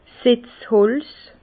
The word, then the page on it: 's Setzholz: Bas-Rhin; Herrlisheim